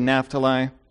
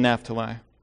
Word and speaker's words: Naphtali: I've heard it pronounced both ways, by the way. I've heard Naphtali by scholars. I've heard Naphtali. We'll go with one. Here's what he says to Naphtali.